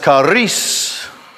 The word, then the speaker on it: charis: Now, there's another word that has the same root that is pronounced charis, charis, which translated is joy, is a grace.